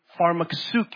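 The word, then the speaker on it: pharmakeia: You guys got to remember the Greek word for witchcraft is pharmakousuke. Did you know that? That's how it's pronounced.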